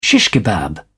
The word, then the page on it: shish kebab: British English